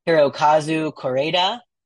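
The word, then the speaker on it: Hirokazu Kore-eda: Hirokazu Koreeda.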